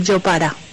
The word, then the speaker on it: Jopara: Eh, la foto también es la foto del yo para se escribe Jopara con J, pero nosotros la J se lee como la Y, como Y. Entonces eh, se dice Yopara en Guaní Es una comida tradicional que se hace cada primero de octubre, así como con todo en el litoral María.